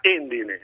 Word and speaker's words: Endine: Endine. La città si chiama Endine. – Ah, allora probabilmente qualcuno mi ha scritto la correzione con l'accento.